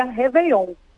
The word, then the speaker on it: Réveillon: Então, ela leva o acento agudo na primeira sílaba, Réveillon, né? Uhum. Mas a gente pronuncia Réveillon.